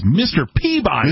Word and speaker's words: Peabody: When I was a kid, I watched a cartoon, and it was not Sherman and Mr. Pibbity. – Right. – Right? – Right. – You're saying it wrong. – It was Mr. Peabody.